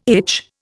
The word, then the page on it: itch: Indian